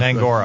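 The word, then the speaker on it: Nyang'oro: We had an African American department chair, Julius Nyangoro. Is that pronounced? – Nangora. – Right? Nangora. I always say that wrong. Nangora, like Angora, like the sweater.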